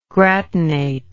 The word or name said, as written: gratinate